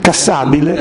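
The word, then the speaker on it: Cassibile: Sì, Cassabile.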